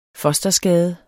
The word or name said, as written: fosterskade